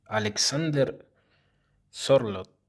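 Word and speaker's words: Sørloth: Alexander Sorloth.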